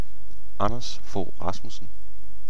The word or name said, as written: Anders Fogh